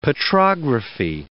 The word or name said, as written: petrography